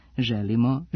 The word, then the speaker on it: želimo: U književno-jezičkoj akcenatskoj normi srpskog jezika prihvaćene su obe varijante, te je u redu da nešto želimo, ali i da želimo.